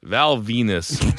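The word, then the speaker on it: Val Venis: Bruce Pritchard is 55, and maybe my favorite kayfabe name of all time, Val Venus, spelled like penis. Is 47.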